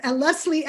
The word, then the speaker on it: Leslie: Yes, well, spelled, so it's Leslie, L-E-S-L-I-E, last name spelled E-H-M, pronounced like the letter M.